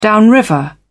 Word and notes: downriver: British English

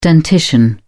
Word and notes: dentition: British English

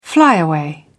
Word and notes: flyaway: British English

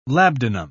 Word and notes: labdanum: British English

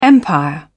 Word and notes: empire: British English

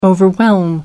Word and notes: overwhelm: American English

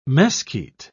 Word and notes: mesquite: British English

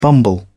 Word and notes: bumble: British English

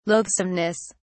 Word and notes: loathsomeness: British English

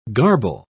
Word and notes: garble: British English

